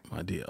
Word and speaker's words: Madea: But that's how you pronounce it, Madea? – Well, it was apparently my uncle, it was his way of saying my dear. And people changed it to Madea.